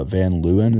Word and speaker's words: Van Leeuwen: So if you have like a salt and straw or a Van Leeuwen, is that how you say it? – Yeah.